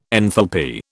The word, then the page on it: enthalpy: British English